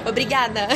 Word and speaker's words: Obrigada: Obrigada. okay. – I hope I said that right. – Yeah, you did.